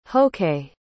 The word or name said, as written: hok'ee